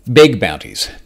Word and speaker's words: beg bounties: Now, I'm not mispronouncing the name. This is beg as in begging, as in you're a beggar, as in someone begging for a bounty. This is not bug, as in there is an actual bug and something useful worth reporting, worth getting paid for.